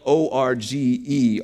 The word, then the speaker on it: orge: And there's a there's the Greek word that you get right here, and it was called for angry was a word called orge, spelled O R G E, orge.